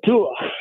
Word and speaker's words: Tua: Tua.